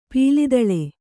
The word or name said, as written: ಪುಲಿದೋಲ್